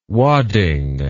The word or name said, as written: wadding